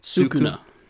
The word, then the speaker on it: Sukuna: Sukuna.